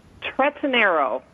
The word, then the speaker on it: Tretinero: It's Tretinero. – Oh, not even close. – Yeah, Ron would have killed that. Yeah, it's phonetic. It's phonetic. It's all E's.